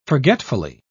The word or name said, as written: forgetfully